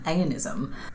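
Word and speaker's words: eonism: The minimizing of clothing was his reason for coining the term aeonism.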